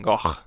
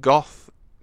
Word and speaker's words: Gogh: It's pronounced Van Gogh. – Is it? – Van yeah. Gogh. Yeah, so it's not Goth, Go, it's Gogh.